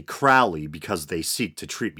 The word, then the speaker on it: Crowley: A lot of people pronounce it Crowley, but I think it's properly pronounced Crowley.